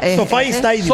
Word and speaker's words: SoFi Stadium: El estadio Sofá. – Sofi Stadium. – Sofi Stadium. – Sofi se escribe. – Derógape. – Pero Sofi se lee.